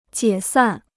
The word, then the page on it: 解散: jiě sàn